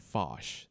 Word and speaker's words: Foch: Marshal Ferdinand Foch, did I say his name? – Yeah, the French.